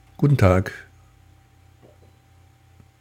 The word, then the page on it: guten Tag: German